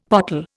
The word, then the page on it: pottle: Indian